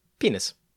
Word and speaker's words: pinus: Did you know the Roman, the Latin word for pine is P-I-N-U-S? And with Latin pronunciations, guess how that's pronounced? – Penus. – Penis. It's literally just pronounced penis.